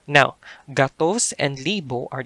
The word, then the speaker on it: gatos: So the word for 100 in Cebuano is gatos. Gatos. That's G-A-T-O-S.